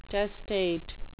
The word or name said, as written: testate